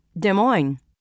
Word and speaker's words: Des Moines: Des Moines.